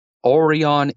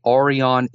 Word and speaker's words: Aurion: So it's Orion Energy. That's AURION.ca. So AURION.ca, Orion Energy.